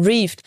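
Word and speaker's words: Reved: Nee, also der Name wird äh, Reeved ausgesprochen. Reeved.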